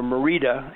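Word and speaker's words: Mérida: Merida, or Merida is the way it's actually pronounced in Spanish.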